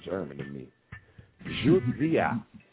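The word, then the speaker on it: Jodi a: And I can't really, I don't know how to tell you to pronounce it, because the J-O sounds like Jew, and the D-I sounds like Z. So Judzi and then I, Judia.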